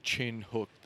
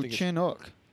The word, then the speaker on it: Chinook: Uh, yeah, I don't, I don't think it's pronounced Chin Hook though. – No, Chinook.